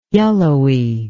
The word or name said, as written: yellowy